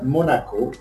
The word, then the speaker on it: Monaco: Monaco, that's the right pronunciation? – Exactly, you're right, Monaco.